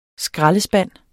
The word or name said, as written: skraldespand